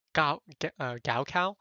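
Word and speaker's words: Gao Cao (高考): Sorry, I'm going to use the Chinese pronunciation because the English pronunciation is a little bit too unnatural for me. Gao Cao?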